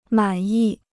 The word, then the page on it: 满意: mǎn yì